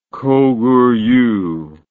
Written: Koguryu

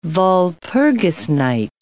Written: Walpurgis Night

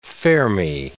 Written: Fermi

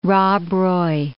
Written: Rob Roy

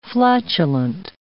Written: flatulent

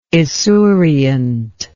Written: esuriently

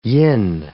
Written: yin

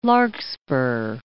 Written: larkspur

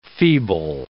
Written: feeble

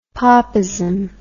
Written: popism

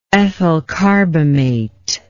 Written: ethylcarbamate

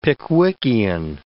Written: Pickwickian

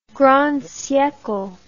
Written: grand siecle

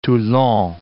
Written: Toulon

